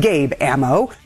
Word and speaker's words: Gabe Amo: Joining me now is Democratic congressman from Rhode Island, Gabe Amo.